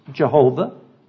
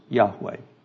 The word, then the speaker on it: Yahweh: Traditionally pronounced Jehovah. Modern scholarship prefers Yahweh.